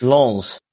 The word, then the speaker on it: Lens: You have to add the S at the end. It's Lens.